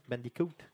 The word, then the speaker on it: Bandicoot: Donc, Crash Bandicoot, c'est bien ça, c'est comme ça que ça se prononce, hein? Bandicoot. – Oui, yes.